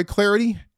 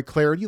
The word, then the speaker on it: Clardy: I'm Troy Clarity. On Twitter, you can follow me at Troy Clarity. The last name is spelled C-L-A-R-D-Y.